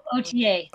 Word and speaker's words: Authier: So to say that correct, right, that's OTA, is that right? – No, OTA. – OTA. – But it's spelled A-U-T-H-I-E-R. But right. – It's like O-T-A, but O-T-A, okay. A-U-T-H-I-E-R.